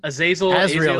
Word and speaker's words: Azrael: Azrael? Is that his name? – Azrael, that's it.